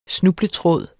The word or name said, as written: snubletråd